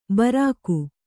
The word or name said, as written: ಭಾರಿಕ